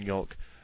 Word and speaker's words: yolk: Did Jake just pronounce the L in yolk?